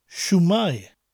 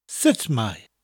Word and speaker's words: Sut mae: It's spelled S-U-T-M-A-E and pronounced in South Wales as Shumai and in North Wales as Sitmai.